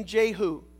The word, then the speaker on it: Jehu: I don't know if you want to pronounce with me the name Jehu. Jehu. Who? Jehu. Who? Jehu. J-E-H-U.